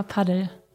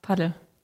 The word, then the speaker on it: Padel: Ich sag immer Paddel. Also nicht Englisch ausgesprochen, nicht unbedingt Spanisch, sondern einfach Paddel.